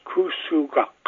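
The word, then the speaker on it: Kusugak: And it's pronounced Kusuwak. The last K is pronounced way in the back of your throat.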